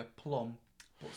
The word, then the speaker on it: plumb: and he called somebody a plum, but spelt it plum, like thumb. – With a B. – It's like a plum. – It's a silent B, that's why.